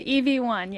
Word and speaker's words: EV1: You were part of the team at General Motors in the late 1990s, and that was, you were working there on the EVI, am I pronouncing that? – The EV1, yes.